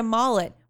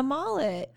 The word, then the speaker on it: mullet: M-U-L-L-E-T. – Yeah, you're saying mullet like a mall. – Add that to my words. – Over and over again. – That's how I pronounce it. – Mull. – Mull? – Mull. – Mull. – Mull. – Like mull. – Just like. – Like mulberry? – Yeah.